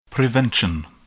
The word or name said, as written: prevention